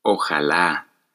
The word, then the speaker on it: ojalá: Una que se usa bastante en México es ojalá, con acento en la última A.